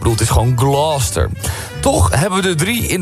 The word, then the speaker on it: Gloucester: Gloucester. De Engelse plaatsnaam Gloucester spreek je uit als... – Gloucester. – Gloucester. – Ja. – Als één vloeiend woord. Gloucester.